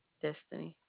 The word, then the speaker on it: Destiny: Destiny. – Can you spell that for me? – D-E-S-T-I-N-Y. – Oh, E-V-S-T-I-V-Y.